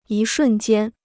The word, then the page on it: 一瞬间: yī shùn jiān